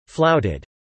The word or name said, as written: flouted